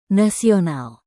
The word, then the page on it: nasional: indonesian